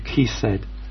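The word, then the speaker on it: chesed: Now, there's someone here who speaks Hebrew, and that word, loving kindness, I think is the word kesed. I'm not sure if I pronounced that right. I'm getting the thumbs up.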